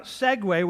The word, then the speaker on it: segue: When I say segue, I'm not talking about the two-wheeled motor device that you'd kind of ride, right? That's a segue, S-E-G-A-Y. No, I'm talking about segue, which is S-E-G-U-E, pronounced exactly the same way because that's the way the English language works.